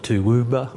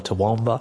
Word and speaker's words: Toowoomba: Und das Erste, was wir klären, ist die Aussprache dieses Stadtnamens mit den vier O's und einem W. – People call it Toowoomba or Toowoomba. It rolls off the tongue pretty easily for us locally, but yeah, I've heard some different ways that people have tried to pronounce it or say what it.